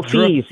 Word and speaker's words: Fils: I didn't know anything about this guy, Arthur Fils. Is that how you say his name? – Who Fils. Yes.